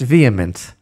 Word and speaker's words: VHEMT: This organization, which is called the Voluntary Human Extinction Movement, so the Rashi Tevot, the acronym, is V-H-E-M-T, but it's pronounced vehement.